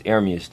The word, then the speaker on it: Ermias: It's Ermias, though. That's how you say it.